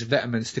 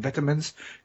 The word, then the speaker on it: vitamins: And I, once again, I am stressing I'm saying vitamins because that is how the word is pronounced. It's not, vi- it's not vitamins, it's vitamins.